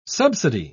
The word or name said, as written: subsidy